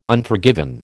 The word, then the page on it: Unforgiven: American English